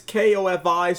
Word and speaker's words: Ko-fi: If you don't know what Ko-fi is, I mean, it might be coffee, but it's K-O-F-I, so I personally pronounce it Ko-fi because that's how it looks to me.